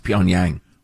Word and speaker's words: Pyongyang: Young people need to stick to their country's, quote, superior language, which is based on the dialects spoken in Puntang. – I believe that's pronounced Pyongyang.